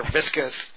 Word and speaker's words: viscous: And the main ingredient is leptin, which is a proprietary blend of vicious polysaccharides. I guess polysaccharides, they get in there and they just beat up those fat cells, right? – Oh, you're mispronouncing it. They're viscous.